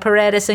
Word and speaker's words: Paredes: They don't have Paredes in goal, who's the Barcelona keeper, because they've been fighting with their federation.